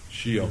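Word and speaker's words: Shio (ᏏᏲ): S in the syllabary, it's S-I-Y-O. But the S is pronounced Shio.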